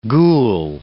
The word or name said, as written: gul